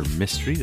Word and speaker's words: Mistry: I'm Dr. Mistry. That is my real name. M I S T R Y. – That's right, mystery.